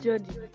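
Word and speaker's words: Jodi: My name is Jody.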